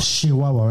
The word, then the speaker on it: Chihuahua: Chihuahua, ¿verdad? Así es. Así se pronuncia.